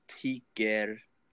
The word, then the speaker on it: tycker: Ticker, like ticker, like T-Y-C-K-E-R,